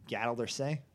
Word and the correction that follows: Galatasaray: I'm pretty sure it's Galatasaray.